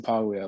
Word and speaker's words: Paglia: I pronounce it Paglia, like the G is kind of soft.